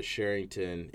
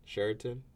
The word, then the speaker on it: Sheraton: He had it at the Sherrington in downtown Norfolk. – The what? – Sheraton.